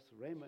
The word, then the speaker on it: Ramah: It says some people say Ramah, but I was told that Ramah is what you say.